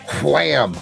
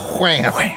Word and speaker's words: Wham: I like I'm Your Man by Wham. I like to pronounce the H, by the way. Wham.